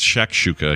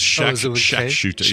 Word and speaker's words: shakshuka: no, it is a, so I'm spelling, I'm actually pronouncing it wrong. It's shakshuka. I keep saying shat with a T. It's shakshuka. Yeah, more like Shaq O'Neal, you know, like a basketball great Shaquille O'Neal's shortcut gotcha.